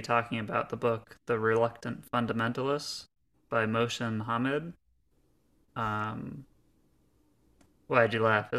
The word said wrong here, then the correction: Mohsin: Is that not how you pronounce his name? – I think you swapped the H and the S. It's Mohsen.